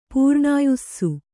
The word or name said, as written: ಪೂರ್ಣಾಯುಸ್ಸು